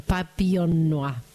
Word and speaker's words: Papillon Noir: Esa es la pronunciación, Papillon Noir.